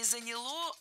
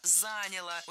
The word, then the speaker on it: заняло: Вот чтобы настроить карбюратор на хорошие пусковые качества для зимы, чтобы он ездил, то это заняло где-то пару дней работы на стенде. – Неплохо. – Нет, плохо, потому что не заняло, а заняло. Ударение не ставим на окончание ни в коем случае.